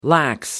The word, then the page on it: lax: American English